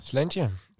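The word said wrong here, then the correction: Slàinte: Slantje. – Oh, zo moet je het uitspreken.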